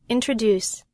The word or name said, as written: introduce